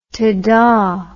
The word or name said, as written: tahdah